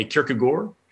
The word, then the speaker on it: Kierkegaard: So yeah, in Danish, the A-A-R-D is sort of swallowed. So you end up much more in a Kierkegaard.